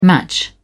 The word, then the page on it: match-up: American English